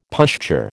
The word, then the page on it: pushchair: American English